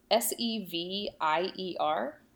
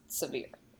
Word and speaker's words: Sevier: Maybe if you've read this word, I just want to let you know it's S E V I E R, but it's pronounced severe. – Because right. If if you were to sound it out, that's sort of what you would guess. – Right. Exactly. Not Sevier, like some people want to say.